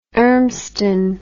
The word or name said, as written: Urmston